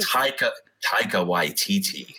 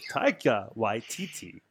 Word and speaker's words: Taika Waititi: Taika Waititi, is it? – Taika Waititi. – Taika Waititi.